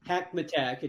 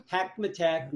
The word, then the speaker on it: Hackmatack: You might invite your listeners to just follow along and verbalize or say Hackmatack. It kind of rhymes with a Big Mac attack, but it's Hackmatack National Wildlife Refuge.